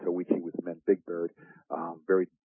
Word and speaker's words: Tahuichi: It's spelled T-A-H-U-I-C-H-I. Tawichi Soccer Academy.